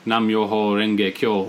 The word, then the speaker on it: Nam-myoho Renge Kyo: And particularly touch on the chant Nam-myoho Renge Kyo, which I have written on my wall here, Which you pronounced extremely well, by the way. I know in your podcast I listened to yesterday, you weren't sure if you were pronouncing it right, but you got it.